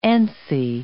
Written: antsy

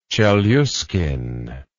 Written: chelyuskin

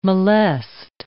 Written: molest

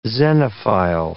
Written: xenophile

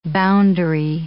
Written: boundary

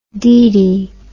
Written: didi

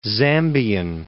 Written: Zambian

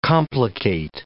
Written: complicate

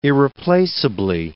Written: irreplaceably

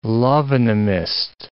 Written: love-in-a-mist

